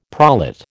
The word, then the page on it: prolate: American English